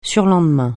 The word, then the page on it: surlendemain: French